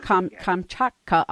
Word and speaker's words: Kamchatka: Kamchatka? – Kamchatka, a BBC special in 2006.